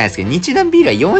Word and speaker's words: 日南麦酒: そんなこんなでね、 6 本分はございますので、ゆっくりゆっくりね、えー、楽しみながら、えー、日南麦酒と書いて日南ビールと読むみたいですけど、この日南ビールシリーズをね、まずはちょっといただきたいなと思います。